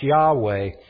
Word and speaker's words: Yahweh: L-O-R-D, Lord. That's the Hebrew, term pronounced Yahweh.